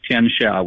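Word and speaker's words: Tianxia (天下): That's what Tianxia, which is how you say it in Chinese.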